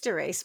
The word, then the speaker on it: Dorice: It's spelled D-O-R-I-C-E. You could call her Doris, I suppose. – No, I think you're right, Doris. – It is Doris,